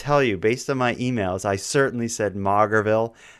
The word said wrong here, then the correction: Maugerville: It's spelled like Maugerville. Mm-hmm. I assumed it was Maugerville. The actual place name is Majorville. As many listeners connected to New Brunswick were kind enough to inform me of my error. – That was a major mistake you made. – Well, the ridiculous town spells it Mauger. Who would pronounce that major? I just assume Mauger, but anyway, it's Majorville.